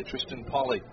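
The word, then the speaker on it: Triston Polley: And I think that's Tristan Polly who will come on. Left-hander, yep, there he is. Finally got the uniform number. So Tristan Polly. First name is spelled T-R-I-S-T-O-N. Last name is P-O-L-L-E-Y, Tristan Polly.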